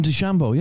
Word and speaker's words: DeChambeau: Is that how you pronounce his name? – Bryson DeChambeau, yeah.